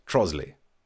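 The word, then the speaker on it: Trottiscliffe: It's spelt Trotterscliff, but of course the posh people, Trosley.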